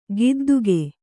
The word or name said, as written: ಗುದ್ದಿಕೆ